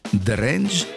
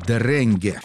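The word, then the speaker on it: Drenge: Начнет его английское трио, название которого можно произносить по-разному. Если по-английски, то это будет «дрэндж», а если по-датски, то это будет «дрэнге». В переводе с датского это означает «мальчики».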